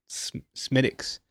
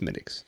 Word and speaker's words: Smithwick's: And there's one's like Smittick's, which is weird because it's spelled Smithwick's, but it's pronounced Smittick's.